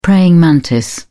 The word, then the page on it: praying mantis: British English